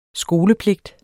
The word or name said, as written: skolepligt